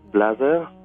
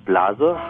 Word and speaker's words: Blaser: Well, I come from the French part of Switzerland, so we pronounce Blaser. – Blaser. – But the Swiss German would pronounce Blaser. And English speaker would say Blazer. So. – Okay. – So well, you can keep Blazer. So that sounds totally fine.